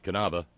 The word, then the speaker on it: Knabe: No, there are no silent letters, not even K's, in German. For instance, Knabe, pronounced Knabe, the English cognate is Knave.